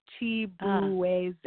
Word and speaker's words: Chibueze: If you see how it's spelled, it looks how it's spelled. It's literally, if you do it phonetically, it's Chibueze. So that's how it kind of sounds.